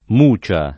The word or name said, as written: mucia